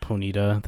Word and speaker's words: Ponyta: Ponyta, that's an A.